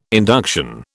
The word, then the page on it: induction: British English